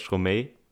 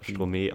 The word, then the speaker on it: Stromae: Nee, das, äh, der Künstler ist Stromae oder Stromae auf Französisch ja. ausgesprochen.